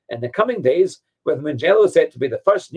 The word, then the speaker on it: Mugello: And on that note, Mugello. Now, I think I'm pronouncing that correctly. I know if Andrew was here, my co-host, he'd be saying, it's not Mugello, it's Mugello. Now,